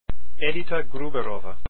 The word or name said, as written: gruberova